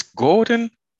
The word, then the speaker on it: Gordon: 11-3, was defeated by Jared Gooden. Or Gordon. I'm sure they were saying Gooden or something like that. It's Gordon, Jared Gordon, who's a tough little guy, man.